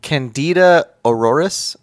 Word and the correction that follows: Candida auris: Candida auris.